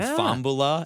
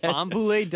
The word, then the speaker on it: Fambula: Apparently his fiance likes that pronunciation better than Fambula, as it's actually supposed to be said. – Fambule does sound cooler.